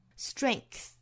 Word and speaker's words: strength: 这个时候在单词内部其实也是会有一个清辅音的一个影响，前面的浊音因为受到后面的清音影响，做浊音清化了，那这个 g 就发成了 k 的发音了。 Strength 那当然还有一种发法，就是这个不发出来了，直接读成 strength 也是可以的。